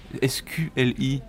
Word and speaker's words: SQLI: SQLI Et déjà est-ce que c'est comme ça que ça se prononce ah, Ça se prononce exactement comme ça et c'est vrai que ça attire toujours un peu l'attention.